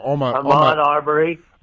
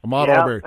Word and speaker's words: Ahmaud Arbery: Is it Ahmaud Arbery or Ahmaud Arbery. – Ahmaud Arbery.